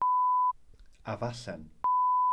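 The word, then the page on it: afallen: Welsh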